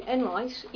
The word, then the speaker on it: Enright: Jackie Linane, L-I-N-N-A-N-E. Caroline Enright, E-N-R-I-G-H-T. – I'm sorry, can you spell that for me again? – Oh, Enright, E-N-R-I-G-H-T.